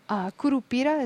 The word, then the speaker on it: Curupira: Tell us about, there's one of the works in the show is Curupira, is that how you say it? – Yes.